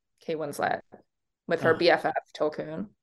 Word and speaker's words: Tolkien: It should really be Avatar for the Tolkiens. – That'd be great. Give the whales a SAG award. – Yeah. Mm-hmm. I hope they got their nomination certificate. They better. – I want to see Tolkien, or how do you say it? – Tolkien.